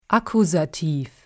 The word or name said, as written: Akkusativ